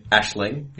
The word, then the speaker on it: Aisling: And it's spelled like Aisling. – Ashling, yeah? – Yeah.